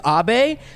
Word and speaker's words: Abe: So my name is Abe, but a lot of times people will Japanese people will sometimes call me Anbe. Because my first name, first letter is actually pronounced on. – Got it. – But when it's a name, you ignore the N.